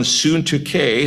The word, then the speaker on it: Syntyche: So the first one, Euodia, and the second one, Syntyche. As far as I can tell, that is the way they are to be pronounced. I don't speak Greek, but that's the way it would have been. But there was a disunity between these two sisters.